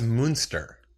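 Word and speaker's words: Muenster: That's, I think, well, that's Munster.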